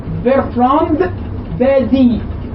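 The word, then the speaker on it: Bertrand Badie: بيرترام يعني بيرتراند الفرنسيين بينطقوه بيرتراند بادي بادي باء ألف دال ياء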